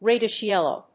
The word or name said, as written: reddish yellow